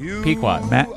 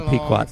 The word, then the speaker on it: Pequot: Do you know, how do you say that? You have no idea. – What do you mean? Pequot. – Pequot, that's it.